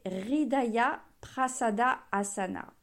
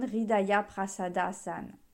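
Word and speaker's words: Hridaya Prasada Asana: Cette posture, c'est RIDAYA Prasada Asana. RIDAYA, donc je vous l'épelle, H-R-I-D-A-Y-A, Prasada, P-R-A-S-A-D-A, Asana, comme une asana, mais le A, on ne le prononce pas.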